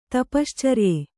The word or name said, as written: ತಪಶ್ಚರಿಯ